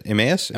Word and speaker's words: Emmaus: Emmaus.